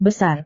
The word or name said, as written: besar